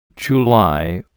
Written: July

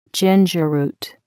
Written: ginger root

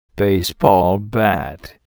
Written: baseball bat

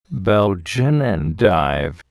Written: Belgian endive

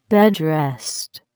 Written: bed rest

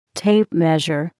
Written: tape measure

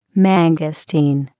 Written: mangosteen

